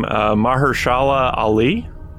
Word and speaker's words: Mahershala Ali: Okay, for me, I'm going to go for, I'm probably going to mispronounce his name, Mahershala Ali.